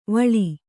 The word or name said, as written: ವಲ